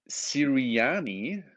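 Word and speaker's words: Sirianni: Die Eagles verpflichten Nick Siriani, richtig ausgesprochen, ja, bislang OC der Colts als neuen Head Coach.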